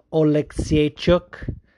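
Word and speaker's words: Oleksiejczuk: By the way, Rhino, take note. Oleksiychuk. That's the proper Polish pronunciation.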